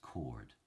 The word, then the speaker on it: coward: Now, coward in original pronunciation is pronounced cord.